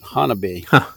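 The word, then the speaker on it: Honobia: That's another place I went with the BFRO, southeastern Oklahoma, near Hanobia. It's spelled, but they pronounce it Hanabi.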